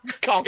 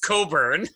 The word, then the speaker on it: Cockburn: Cockburn. It's pronounced Coburn.